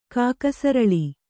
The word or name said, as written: ಕಾಕಸರಳಿ